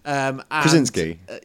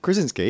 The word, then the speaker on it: Krasinski: So we've got John Kransky. From Quiet Place. – Krasinski. – John, is that how you say it? Kransky? Krasinski? – Krasinski, yeah.